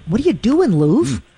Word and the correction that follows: Louvre: Will you please pronounce that correctly? – Is it Louvre? – Louvre. – Yeah, there you go. – Louvre. I did. I talked. Thank you for listening, Al. I did talk about that. It's one of the most mispronounced words. It's Louvre. I just feel silly saying that with my American accent.